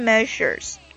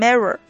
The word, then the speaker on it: measure: 最后还有一个这个 preparation measures，这个短语是准备措施的意思。这里有一个读音的问题，大家一定要注意这个 measure 这个辅音日啊，我感觉这个应该是对很多中国学生都很造成困扰的一个读音，能把这个音发好的这个中国学生不是很多哈。很多中国学生会把这个 measure 啊读成 mirror 啊，是吧，就有点像这个我们中文中的这个日，但实际上这个辅音呢应该摩擦的更强烈一些。